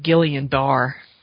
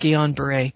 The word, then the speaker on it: Guillain-Barré: Guillain-Barré, it's spelled like Guillain-Barré, but it's pronounced Guillain-Barré.